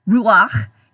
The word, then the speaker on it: ruakh: The Hebrew word used here for God's Spirit is the word ruakh, and it's Hebrew, and it's spelled a couple of different ways, but predominantly R-U-A-K-H, ruakh.